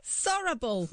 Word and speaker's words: Sorabol: Sorrible. I'm not even joking. It's spelled S-O-R-A-B-O-L.